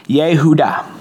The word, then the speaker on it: Yehuda: Now, in Hebrew, you pronounce Judah as Yehuda. You take that over to the Greek, and you get Eudas. And in English, we say Judas.